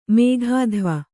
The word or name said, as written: ಮೇಘಾಧ್ವ